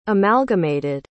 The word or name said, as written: amalgamated